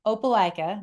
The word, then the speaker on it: Opelika: So, and kind of, if you can kind of pan out just a little bit, your town, Opelika, am I saying that right? – Yeah, you're saying it right.